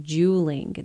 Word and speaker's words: juuling: Let's talk about juuling. Now, that's J-U-U-L-I-N-G. It's pronounced juuling.